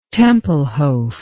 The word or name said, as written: Tempelhof